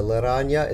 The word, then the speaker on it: laranja: This, Laranja. Is it pronounced Laranja or Laranja? Laranja. – Laranja, okay.